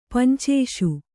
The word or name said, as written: ಪಂಚೇಷು